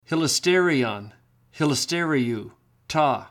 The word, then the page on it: hilasterion: Greek